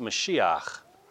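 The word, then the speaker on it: Mashiach (מָשִׁיחַ): we'd be saying out loud a word that is pronounced Mashiach. You say, preacher, that almost sounds like Messiah, because it is.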